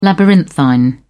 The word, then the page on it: labyrinthine: British English